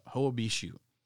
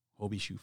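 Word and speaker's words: Hoia Baciu: Hoabishu. And it's spelled Hoabaciu, Hoabishu Forest.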